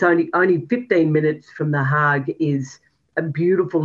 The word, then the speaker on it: Den Haag: Can I just ask, do you pronounce it the Hague or the Haag? I should know from my Dutch background, of course. – Well, I think it's the Haag, but please, someone will come and tell me I'm wrong.